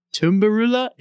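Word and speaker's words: tunnbrödsrulle: I think the only term I might remember, because I tried practicing it a lot, is a Stockholm street food, I think, called a tumbarula. Is that how you pronounce it? – Oh, nice. Yeah, it is.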